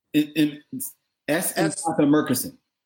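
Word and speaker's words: S. Epatha Merkerson: And I always mispronounce her name. Apatha. – Essampatha Merkerson.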